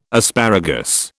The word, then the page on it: asparagus: British English